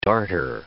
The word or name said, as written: darter